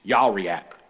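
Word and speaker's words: Yariak (יריחו): It's like Yarich. Yariak is how you say that.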